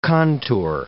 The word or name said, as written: contour